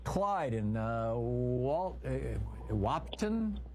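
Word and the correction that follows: Wahpeton: North Dakota. Am I saying it right, Clyde? – Yes, Wapiton, North Dakota.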